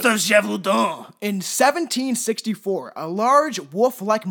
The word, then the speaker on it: Gévaudan: I had to look up how this was pronounced because I would have pronounced it like Gévaudan. I would have pronounced it stupidly.